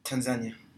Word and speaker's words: Tanzania: How do you say it? – Tanzania.